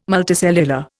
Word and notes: multicellular: Indian

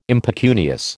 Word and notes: impecunious: American English

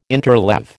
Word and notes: interleave: American English

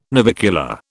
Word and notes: navicular: British English

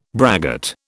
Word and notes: braggart: British English

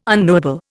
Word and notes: unknowable: Indian

